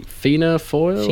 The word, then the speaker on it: Fianna Fáil: Now we've had a text sort of picking me up on my pronunciation, saying it's actually Fianna Fáil.